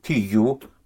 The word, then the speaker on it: tu: The how to use it is very simple, because tu, T-U, pronounced tu, again, you have the U.